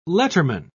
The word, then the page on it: letterman: British English